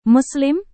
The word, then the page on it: muslim: Indonesian